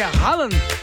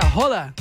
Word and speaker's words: Haaland: O próprio Haaland, todo mundo fala que é Haaland. Na verdade, a pronúncia é Rola. É, ele mesmo fala.